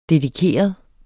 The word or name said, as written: dedikeret